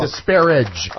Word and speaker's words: disparage: Disparage. – Oh, there's three syllables, not two.